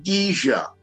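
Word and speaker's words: GIJIA: It's pronounced Gija.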